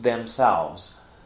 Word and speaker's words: themselves: So themselves because, earlier in the sentence I have the subject children, and that's plural, so I would use themselves. Notice that the pronunciation here, themselves, with a Z sound at the end or a VZ sound at the end.